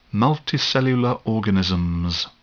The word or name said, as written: multicellular organism